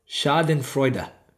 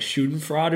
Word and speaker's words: Schadenfreude: You know, that it's pronounced Schadenfreude. I had always seen that word and never knew. I knew what it meant, but just never bothered to figure out the pronunciation. It's just too many weird things there. And I was like, okay, I know that. In my head, it would have said like Schadenfreude or something.